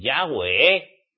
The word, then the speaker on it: Yahweh: hay que pronunciar su nombre, Yahweh, no YHWH como hacéis muchos, esos son siglas.